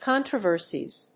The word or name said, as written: controversies